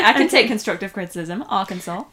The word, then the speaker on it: Arkansas: Did you know that's pronounced Arkansas? – I did not.